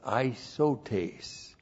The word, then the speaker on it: isotes: I-S-O-T, long E-S, isotes.